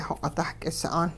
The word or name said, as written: xoq'it-dahk'is'aan